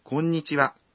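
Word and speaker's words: 곤니치와 (こんにちは): 곤니치와. – 안녕하세요. 주로 낮 시간대에 사용하는 인사말입니다. – 곤니치와의 발음이 어려워요. – 네, 그렇죠. 공, 니찌, 와 라는 식으로 한 음절씩 나눠서 발음해 보면 좋죠.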